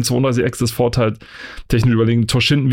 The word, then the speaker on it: Toshinden: Und in der blauen Ecke der neue Herausforderer aus Japan, Toshinden. Habe ich das hier ausgesprochen? – Toshinden, Toshinden, ja. Toshinden, ja.